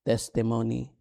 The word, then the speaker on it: testimony: Not testimony, it's testimony. Emphasis. The emphasis is in the first syllable. Testimony. Did you say that? Do you know that? That is how you pronounce it. Testimony, not testimony.